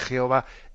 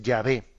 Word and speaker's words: Yahvé: Luego los estudios filológicos, pues tuvieron más conciencia, avanzaron más y llegaron a entender que la pronunciación más correcta, más que Jehová, es Yahvé.